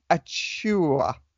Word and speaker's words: Achiuwa: And we are going to, chat some of the Heat's 20th pick, our brand new rookie, and this is the first time I'm pronouncing his name live, Precious Achiuwa. – Yes, that's right. – Is that anything Precious like Achiuwa. Right? – Yeah, that's perfect.